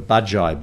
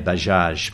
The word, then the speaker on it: Bajai: We were called the Bajai Boys. – That's how you say it. – Yes, it looks like Bajaj. And in fact, in India, they say Bajaj. But right. In Indonesia, they call it Bajai and they also call it Tuk Tuk.